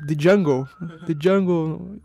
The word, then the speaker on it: Django: The Jungle. E aí o cara... O D é mudo.